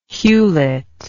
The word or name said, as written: hewlett